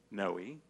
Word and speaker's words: Noe: Okay, my name is Noe, which is spelled N-O-E.